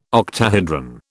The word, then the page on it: octahedron: British English